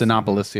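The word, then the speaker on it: Cinépolis: Well, a theater chain, a Mexican theater chain, I think it's pronounced Cinepolis. Yeah, as in a cinema metropolis. – Cinepolis, yeah.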